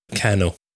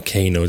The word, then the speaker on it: Kano: A youngster in your life, and that's Kano, or Kano, depending on how you want to pronounce it, K-A-N-O.